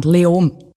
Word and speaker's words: Leoben: Spricht man es Löwen aus? Wie spricht man das überhaupt aus? – Eigentlich fast kein Konsonant. Leom.